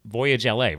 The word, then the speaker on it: Voyage LA: And I love the fact you actually have linked into your site an article on you guys from, how you pronounce that? Like Voyage LA, right? Like at Los Angeles? – Yep.